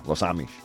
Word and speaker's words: Los Amish: A really funny story about how some Amish men, members of the Amish community, Los Amish, I think you say it right in Spanish. Right? Yeah. Los Amish.